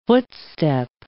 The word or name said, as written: footstep